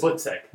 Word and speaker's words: Butzek: Did I pronounce that right? – You got it pretty good, yeah. – What is it? What's Butzek.